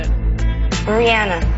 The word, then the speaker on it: Rihanna: Rihanna. I said Rihanna, but Rihanna is still okay. – So that's how she pronounces her name, Rihanna.